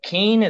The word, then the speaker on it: Keene: But Keen, is that how you say it? Keen? – K-E-E-N-E.